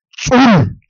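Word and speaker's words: Dzul: Así como se escucha, tzul, tzul, es otro apellido. Se escribe Z-D-Z-U-L, tzul, tzul. Y quiero aclararles que en la escritura maya, la D-Z es una sola letra, a diferente del castellano que son dos.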